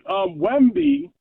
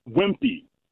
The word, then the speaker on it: Wemby: All right, how about your Wemby maybe just doesn't have any heart? I'm sorry, not Wemby. I said his name wrong. Wemby.